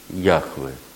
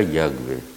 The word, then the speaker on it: Яхве: И сейчас вот в библистике западной можно встретить вот Яхве, иногда Ягве, ну, больше, точнее, Хэ, там такая буква, которая может, которая является аналогичной немецкому Ха, который мы произносим как Хэ.